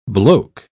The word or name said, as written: bloke